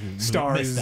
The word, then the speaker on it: Starz: Thank you for pronouncing that correctly. – Yeah, you got to say that. Stars.